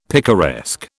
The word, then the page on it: picaresque: British English